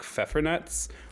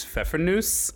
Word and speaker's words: Pfeffernuss: I'm actually pretty sure the correct German pronunciation is Pfeffernuss, but we always called them Pfeffernuts growing up.